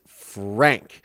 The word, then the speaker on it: Franke: You say it's Frank. Good to know, because I've heard it pronounced two ways. I heard the lawyer pronounce it Franke, and I've heard the news agencies pronounce it Franke, and I've heard other people pronounce it Frank.